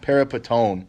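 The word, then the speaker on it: peripatōn: and it's peripaton, or peripaton rather. You may remember my Greek pronunciation, peripaton, and it means, it's a participle, it means the one who is walking.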